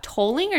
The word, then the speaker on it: tolling: And they're clearly a Canadian dog because they're a Nova Scotia duck trolling retreat. Duck tolling or duck trolling? – Tolling.